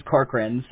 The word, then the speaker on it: Corcoran: My last name's Corcoran, so... And everybody always says Cochran, you know. It's like, nobody ever... – They see it, how they spell it. – Well, it's spelled Corcoran. – Oh. – So, but they don't say Kokorin.